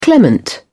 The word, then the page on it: clement: British English